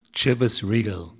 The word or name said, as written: Chivas Regal